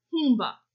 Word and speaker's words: humba: They made something called humba. I think I'm saying it right. – No, that's right, yeah.